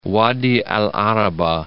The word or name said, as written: Wadi el-'Arabah